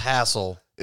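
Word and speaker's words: Hasil: Tonight we have Hazel Atkins, or Hassel Atkins. – It's actually pronounced Hassel. – Is it? It's H-A-S-I-L.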